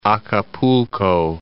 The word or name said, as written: Acapulco